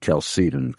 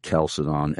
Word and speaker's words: Chalcedon: But I believe that those in your organization pronounce it Chalcedon, correct? – That is correct. – And not Chalcedon and not Chalcedon or Chalcedon.